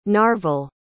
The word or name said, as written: narvel